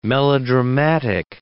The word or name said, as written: melodramatic